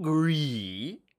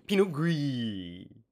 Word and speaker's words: Pinot Gris: Pinot Gris. Not Grigio. – Gris. – Not Noir. – Just Gris. – Pinot Gris. Which is spelled G-R-I-S, like Grizz. Like Gris.